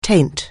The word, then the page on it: taint: anglais